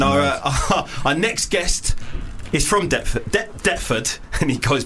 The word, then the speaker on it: Deptford: Deptford is a fucking annoying word because it's got a silent P in it, and I only figured that out like a week ago.